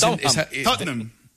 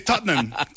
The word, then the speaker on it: Tottenham: Enséñanos, porque es que es Tottenham. – Tottenham. – Son dos sílabas. – Ajá. Es difícil, ¿no? – Nosotros los británicos. Pero... – ¿Solo dos sílabas? – Sí, Tottenham.